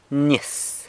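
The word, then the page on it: nyis: San Lucas Quiaviní Zapotec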